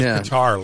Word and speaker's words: Qatar: Well, we knew there were going to be problems in Qatar, or however we pronounce that stupid Qatar. – Country name. – Yeah, we're going to go with Qatar, like playing a Qatar.